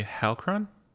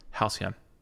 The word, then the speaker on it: Halcyon: The Halcron? No, there's no R. – Halcyon? – The Holocron. – Omicron Persei 8. – Halcyon.